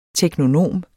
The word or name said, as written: teknonom